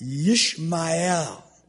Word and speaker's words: Ishmael: His name is Ishmael. Ishmael. It's pronounced Yishmael.